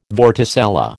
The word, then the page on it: vorticella: American English